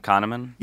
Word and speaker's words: Kahneman: Oh, Daniel Kahneman? – Yeah.